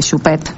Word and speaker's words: Choupet: Una de las mujeres que cuidaba a Choupet, quiero que sepan que practiqué cómo se dice Choupet.